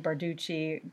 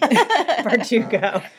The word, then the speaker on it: Bardugo: I keep saying Barducci, Barlucci, Bardugo.